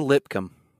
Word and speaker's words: Lipscomb: I did find out the S is silent on that name. Oh, so it's Kalijah Lipcomb.